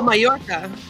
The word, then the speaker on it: Mallorca: Oh, Mallorca.